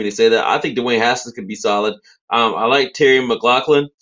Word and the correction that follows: McLaurin: No, McLaurin. McLaurin. M-C-L-A-U-R-I-N. – No, it didn't sound right. Okay. Whatever. I knew it didn't sound right saying McLaughlin.